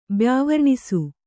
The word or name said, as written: ಬ್ಯಾವರ್ಣಿಸು